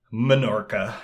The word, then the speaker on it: Menorca: We shot that in Spain in Menorca. I got to make sure I say that right. Not Mallorca, Menorca, I know those two, that's it.